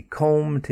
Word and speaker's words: comb: Comb, he combed his hair quite quickly. Comb, and with that word we've got the silent B at the end, C-O-M-B, to comb or to brush.